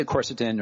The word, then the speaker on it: quercetin: Quercetin. Am I saying that right? – I say quercetin or either quercetin. – One. – Quercetin. Okay.